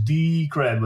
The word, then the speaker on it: Decred: But the official way to pronounce Decred is Decred with a long D, and the D comes from the word decentralized.